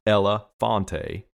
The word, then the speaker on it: Elefante: When we purchased this toy, dubbed the Elefante, pronounced Ella Fonte.